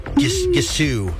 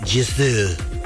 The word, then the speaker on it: Gisou: Instead of an S, it's more of like a Z. Like, they literally have the word zoo on here. Gisou.